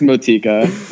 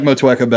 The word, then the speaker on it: Motueka: Is that how it's pronounced? – No, it's Motika. – Yeah. – I like Motueka better.